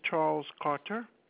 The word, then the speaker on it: Charles Carter: My name is Charles Carter.